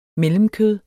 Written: mellemkød

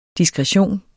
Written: DISKRETIONENS

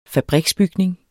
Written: fabriksbygning

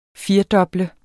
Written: firedoble

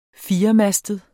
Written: firemastet

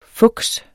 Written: fuks

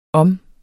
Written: om

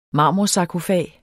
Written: marmorsarkofag

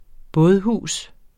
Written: bådehus

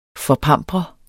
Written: forpampre

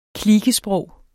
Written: klikesprog

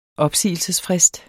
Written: opsigelsesfrist

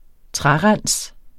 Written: trærens

